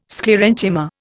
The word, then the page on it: Sclerenchyma: Indian